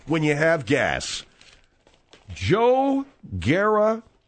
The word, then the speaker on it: Garagiola: I knew you'd butcher it. Joe Garagiola.